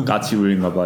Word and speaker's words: 까치울: 네, 까치울. – 외국인한테 발음하기 가장 어려운 지하철역 중 하나. 까치울. – 쌍둥이 어렵죠. – 네. – 기억하고. – 그리고 울도 쉽지 않잖아요. – 아, 울도 쉽지 않아요? – 네.